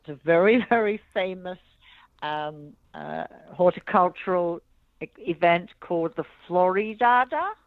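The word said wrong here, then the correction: Floriade: I'm not pronouncing that Floriad. – Correctly. Floriad? Yeah, thank you.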